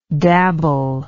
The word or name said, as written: dabblingly